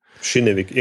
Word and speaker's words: Kinnevik: Also ich meine, wenn ich jetzt als, als äh, Kinewick oder äh, wie, wie spricht man das aus? Chinevik? – Chinevik, irgendwie mit Sch Chinevik.